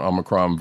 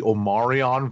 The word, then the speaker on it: Omicron: I'm glad that Roddy Cat pronounced it correctly and did not call it the Omarion variant,